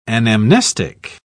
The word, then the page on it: anamnestic: British English